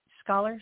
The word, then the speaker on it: Scalar: And so I couldn't handle that until I had Atlantis Scaler. So am I pronouncing that right? Scholar. – Perfectly.